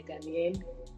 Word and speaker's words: Ghanaian: Guyanian? – What? – How do you say it? Guyanian? – Guyanian. – Guyanian? – Yeah, Ghanaian.